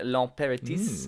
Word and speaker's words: L'Impératrice: Okay, song number nine from me is uh, Agitations Tropicales by L'Impératrice.